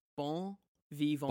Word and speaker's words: bon vivant: Bon vivant.